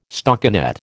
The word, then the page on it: stockinet: American English